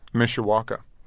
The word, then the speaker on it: Mishawaka: Mishawaka.